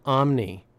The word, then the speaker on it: OMNY: And this system is called Omni, you know, like omniscient or omnipresent. Surveillance, yeah, that's what it's, except it's spelled O-M-N-Y.